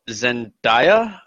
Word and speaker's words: Zendaya: Zendaya, I believe it's pronounced.